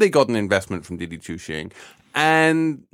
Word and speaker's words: Didi Chuxing: Didi Chuxing. – Didi Chuxing.